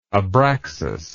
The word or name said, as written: abraxas